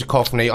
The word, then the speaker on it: Duchovny: I love David Duchovny. I don't even... Is that how you pronounce his surname? – Yes, I think it is.